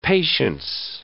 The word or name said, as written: patience